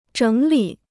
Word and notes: 整理: zhěng lǐ

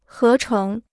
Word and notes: 合成: hé chéng

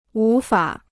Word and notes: 无法: wú fǎ

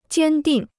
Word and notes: 坚定: jiān dìng

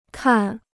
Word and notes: 看: kàn

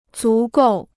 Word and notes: 足够: zú gòu

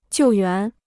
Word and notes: 救援: jiù yuán

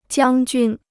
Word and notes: 将军: jiāng jūn

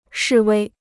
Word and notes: 示威: shì wēi

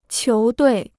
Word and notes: 球队: qiú duì

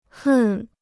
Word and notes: 恨: hèn

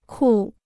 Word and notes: 酷: kù

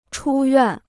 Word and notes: 出院: chū yuàn

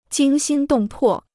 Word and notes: 惊心动魄: jīng xīn dòng pò

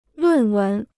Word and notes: 论文: lùn wén

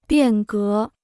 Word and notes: 变革: biàn gé

